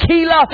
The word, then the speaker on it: Keilah: they were in a town called Keilah. It's spelled K-E-I-L-A-H.